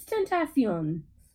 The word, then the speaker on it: Tentacion: It's Tentacion. Tentacion.